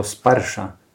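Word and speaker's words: sparsha (स्पर्श): Normalnie byłoby to słowo sparsha, które oznacza dotyk. – But if the gopis just said sparsha, it would be one syllable too short.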